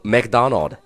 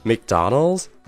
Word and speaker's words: McDonald's: 你是不是还在说 McDonald？那我就知道你要这么说，其实肯定是不对的。那今天我就利用这个机会来教你一下麦当劳的英文应该怎么去说。那么它的拼写是 M C D O N A L D apostrophe S，它正确的读音其实是 McDonald's or McDonald's。